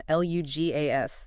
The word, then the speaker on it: LUGAS: We're focusing today on LUGAS.